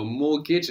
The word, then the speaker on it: mortgage: So mortgage, and we have that T that we don't pronounce in mortgage.